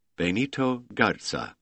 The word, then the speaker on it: Benito Garza: He pronounced each syllable carefully, drawing it out in the Mexican way. Benito Garza, with exaggerated accent on the knee and gar.